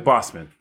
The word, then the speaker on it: Boseman: Chadwick Boseman.